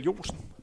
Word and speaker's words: Jonsen: Stor respekt skal også lyde for hans træningskammerat, øh, Michael Jonsen. Hans efternavn er udtalt korrekt, er det ikke? Ja, det er korrekt.